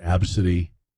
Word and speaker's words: ABCDE: There was a kid named ABCDE, and they pronounced it Absidy.